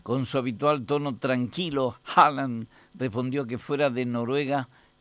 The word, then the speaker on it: Haaland: Es Haaland, marcando bien la A. Es Haaland.